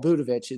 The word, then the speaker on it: Budovich: So if you need to know how to spell Budovich, it's B-U-D as in David, which is your name, David, A-V as in Victor, and then I-C-H is how that's spelled. So.